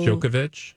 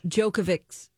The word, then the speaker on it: Djokovic: It's D-J-O-K-O. – Djokovic? – Djokovic's box. – Yeah. – He was in his actual box. – His box. – Why are you here? – That's what Djokovic, what's his name? – Djokovic said.